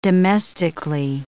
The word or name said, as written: domestically